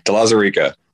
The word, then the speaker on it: De Lauzirika: De Lauzirika.